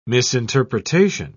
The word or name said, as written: misinterpretation